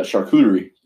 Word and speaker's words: charcuterie: Oh, yeah. No, that's a charcuterie. – Oh, well, I might have said it wrong.